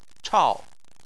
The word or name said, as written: chao4